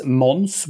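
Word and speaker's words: Måns: So my name is Mons, which is a Swedish way of pronouncing this.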